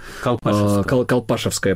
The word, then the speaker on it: Колпашевское: Колпашевское, правильное ударение.